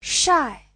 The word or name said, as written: shai4